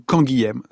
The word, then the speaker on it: Canguilhem: Philosophe, médecin, résistant, Georges Canguilhem, ou Canguilhem, selon qu'on adopte la prononciation de sa région natale dans le sud de la France, à Castelnaudary.